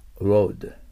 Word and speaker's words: road: 道路の方のロードは、うの口ですから、ベロが下、ロード。で、ライド、乗るの過去形のロードも、発音同じだけど、ややこしいですよ。